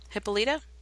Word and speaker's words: Hippolyta: Is it Hippolyta? Hippolyta? – Hippolyta. – There you go. Hippolyta? That sounds better.